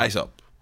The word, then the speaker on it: Aesop: Aesop. Like Aesop's Fables. – But don't you pronounce it Aesop's Fables? – No, he calls himself Ace.